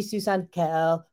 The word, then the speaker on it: Suson-Calhoun: This is Jody Susan Calhoun. Okay, I didn't do that right because when my soul sisters from the other state, from the other uh, versions of the show, when they pronounce my name, they go jo- Reverend Jodi Suson-Calhoun. Okay, so that's a wake up call.